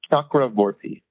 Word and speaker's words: Chakravorty: It's um, Chakravorty.